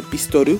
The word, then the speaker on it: Pistoru: Entonces lo complementan con creo que son sílabas, que es eh, Pistoru, no, o sea, no existe Pistol, existe Pistoru, que es, una mm, un inglés pues adaptado, ¿no? Por así decirlo, allá en Japón. Entonces, pues por eso hablan así.